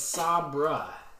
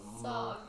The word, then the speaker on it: Sabra: It's Sabra. – What? – Which I guess you could long A that ending A. – Sabre.